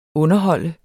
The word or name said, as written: underhold